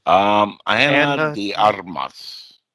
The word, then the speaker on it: Ana de Armas: Arma de Amis. I feel like I butchered that name probably. – Ana de Armas.